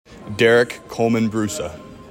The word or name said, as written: DEREK COLMAN-BRUSA